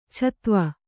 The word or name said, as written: ಛತ್ವ